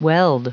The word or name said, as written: weld